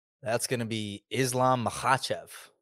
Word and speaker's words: Makhachev: So we're emphasizing the second syllable, right? So it's not Makhachev, right? It's Makhachev, right?